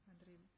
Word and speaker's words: Madrid: Madrid.